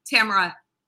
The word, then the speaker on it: Tamara: Tamara.